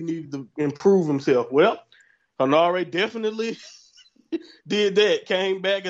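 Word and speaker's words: Henare: And Aaron Hinare, is that the right way to pronounce it? Defeated... – Hinare.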